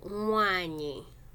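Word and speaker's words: nwanyị: So mwanyi is spelled, so it's spelled N-W-A-N. Y-I. So we've talked about, now we're looking at N-W. So the next letter right after N-W is N-Y, which is at the end of Mwanyi.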